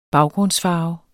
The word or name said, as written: baggrundsfarve